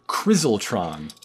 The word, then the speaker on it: Crizltron: Crizzletron.